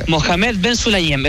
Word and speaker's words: Mohamed Ben Sulayem: Mohamed Ben Sulayem, el presidente de la FIA que estaba...